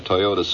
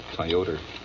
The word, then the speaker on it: Toyota: Toyota. Huh? Of course, I'm pronouncing it the way it's pronounced in the Bronx. Toyota. But, you know, they're really Toyota.